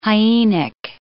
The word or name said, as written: hyenic